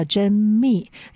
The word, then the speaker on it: 甄宓: 那在根据正史也记载哈，这个在官渡之战呢，曹操当时其实打赢之后呢，他其实虏获了一个非常出名的美人，那个美人的名字叫甄宓，或者也有发音叫甄宓，因为那个宓是一个秘密的宓，下面不要山啊。